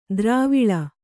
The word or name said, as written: ದ್ರಾವಿಳ